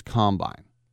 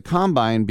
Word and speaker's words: combine: And remember, in football, you put the emphasis on the second syllable. It's not offense, defense, and combine. It's offense, defense, combine.